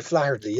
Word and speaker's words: Flaherty: Robert Flaherty? Is that how you say his name? – Robert Flaherty, yeah.